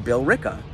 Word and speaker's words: Billerica: You're wrong. It's fucking pronounced Billerica.